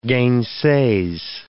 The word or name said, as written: gainsays